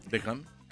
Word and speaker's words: Beckham: Victoria Beckham. – Claro. – No puedes pronunciar normal. – Beckham.